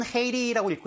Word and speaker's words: Haiti: 영어로는 Haiti라고 읽고요. 우리말로는 IT라고 읽는 나라가 되겠습니다.